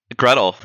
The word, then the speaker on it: Gretel: Or is it pronounced Gretl? Sorry. – It's a long one. And, you know, Gretl, yes, it's a long one.